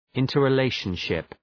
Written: interrelationship

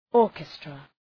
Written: orchestra

